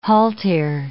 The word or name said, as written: haltere